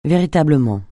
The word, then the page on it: véritablement: francais